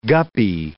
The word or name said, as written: guppy